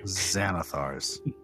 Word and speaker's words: Xanathar: Xanathar's. – Yeah. It's 100% pronounced Xanathar.